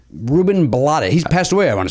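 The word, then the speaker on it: Rubén Blades: I always want to pronounce blades Blottis because of the actor Ruben Blottis. – He got in your head, didn't he? – Is it Ruben Blottis? – Yeah.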